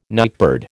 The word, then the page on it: nightbird: American English